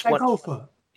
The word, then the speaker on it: Sankofa: And the reason why I ask is because one of the things that you talk about is a Ghana African symbol called, I'm going to say it wrong, Sankofa. – And yes, Sankofa.